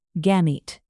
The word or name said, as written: gamete